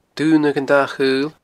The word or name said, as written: Dun Adhan a Chaoil